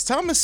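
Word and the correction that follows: Tomáš: Satoransky, Tomas Satoransky, excuse me, let me say his name correctly.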